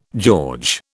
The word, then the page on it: George: British English